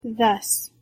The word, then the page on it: thus: English